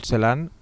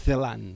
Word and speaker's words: Celan: Però on fiques l'accent tu? – Uh, paraula plana. Paraula plana. – En, bueno, L'altre dia algun... pronunciant una... com, com ho pronunciava. Celen. Els francesos diuen Celon.